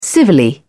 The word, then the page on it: civilly: British English